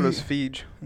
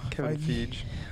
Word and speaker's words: Feige: Is that how you pronounce his name? I thought it was Feige. – No. – Kevin Feige.